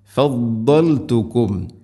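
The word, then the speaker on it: فَضَّلْتُكُمْ: ഫദ്ദൽതുകും, ഓതിപ്പോകുന്നത് പലപ്പോഴും ഫല്ലൽതുക്കും എന്നായിരിക്കും. ഫല്ലൽതുക്കും അല്ല ഫദ്ദൽതുക്കും.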